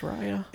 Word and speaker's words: Raya: Is that pronounced? I thought it was Raya. I'll take your word for it. Raya and the Last Dragon. – I think it's Raya. – It is.